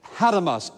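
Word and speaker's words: hatomos (ἄτομος): In the Greek, the word is hatomos, or A-T-O-M-O-S, which sounds like the word what? Atom.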